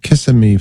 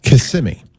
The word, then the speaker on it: Kissimmee: Eduardo is in Kissimmee, Florida. I said that wrong. Kissimmee is how you pronounce that.